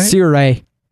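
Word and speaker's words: Sirui: Is it Siray? Is that right? – Siray. – So say that again? – Siray. – Okay. – Siray. – It's not how it's spelled at all.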